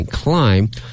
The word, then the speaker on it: climb: 这次注意啊，发音那个 b 啊是不发音 climb， five places，爬了五名，到达一个什么样的一个位置呢？